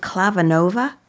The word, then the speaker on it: Clavinova: Now, the concert that I remember you gave, and this, is it Clavinova? – Yes. – Am I saying that correctly? – You are.